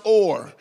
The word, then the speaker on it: Ur: Here is a place called Ur. It looks like Ur. If you read it in the Bible, you'll pronounce it as Ur. It's U-R. But it's not pronounced you are. In Hebrew, it's actually pronounced or.